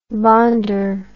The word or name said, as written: bonder